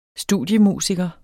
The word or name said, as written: studiemusiker